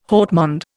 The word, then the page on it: haut monde: Indian